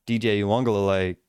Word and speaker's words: Uiagalelei: And instead of trying to get a big hit, what he does is he sees DJ Uiagalelei rearing back for a pass.